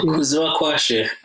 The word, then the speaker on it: Kuziva Kwashe: So Kuziva Kwashe, it's I'll spell it out for you guys so you can picture how it's written. It's spelled KUZIVAKWASHE. So the pronunciation is Kuzivakwashe.